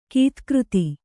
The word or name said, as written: ಗದುಗದ